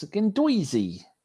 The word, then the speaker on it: Guendouzi: People want to say how I say the G bloke is Guendouzi. That's officially how you say it.